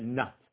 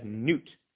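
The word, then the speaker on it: nut (נאט): In our way of pronouncing, we would probably pronounce that nut. I've seen this referenced as Newt, Rabbi Simon Newt. I don't know why you would say that if that's how it's spelled, but okay.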